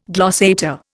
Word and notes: glossator: Indian

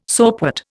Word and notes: soapwort: Indian

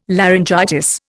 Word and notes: laryngitis: Indian

